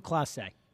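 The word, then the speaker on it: Clase: Class A, is that how you say his name? Yeah, Manuel Class A.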